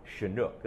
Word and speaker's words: Chinook: Chinook, that's right.